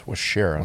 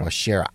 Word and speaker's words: Waushara: The cabin is presented to you by the Wisconsin Counties Association, and this week we're featuring Washara County. I'm pronouncing that right? – It's Washara. – Washara.